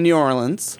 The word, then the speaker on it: New Orleans: Now, so you set your first book in New Orleans. – I did. – Or New Orleans? – No, you said it right the first time.